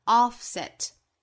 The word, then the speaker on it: offset: offset 这个词第一个元音在美音之中上下开口读更松更开，发 o 的发音 offset negative indirections。